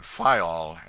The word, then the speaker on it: Faial: The people there pronounce it Fayal, and put the accent on the first syllable.